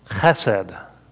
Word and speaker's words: chesed: The word in Hebrew, chesed. Say it with me. Chesed. If you didn't spit on the person in front of you, you didn't say it right.